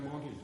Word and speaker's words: Menteith: And the pronunciation is Monty. – Monty. – As in Dennis Monty played for Australia. My father played for the Queensland Reds. – I'm sorry if I've been... – They all say Monty, you know, but that's the way it's pronounced.